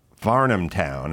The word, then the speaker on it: Varnamtown: So the podcast, Varnum Town, if I'm saying that right.